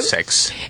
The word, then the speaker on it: SpaceX: SpaceX, dije bien. – SpaceX.